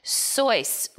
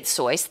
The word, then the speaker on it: Seuss: So what this book says is that he thought his name, well, he pronounced his name Seuss, rhyming with voice. – Ah. – And no one else said Seuss.